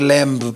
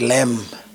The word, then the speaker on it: lamb: the B is silent. It's not lamb, but lamb.